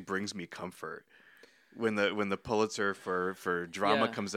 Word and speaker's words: Pulitzer: And every year when the Pulitzers get announced, Pulitzer, how do you say Pulitzer.